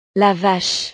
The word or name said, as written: la vache